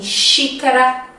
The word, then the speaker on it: xícara: Som do X nós encontramos em xícara, nós encontramos em shampoo. Sim, Shampoo em português se escreve com X, tá? E também na palavra paixão. Sim, paixão se escreve com X também no português. Ok?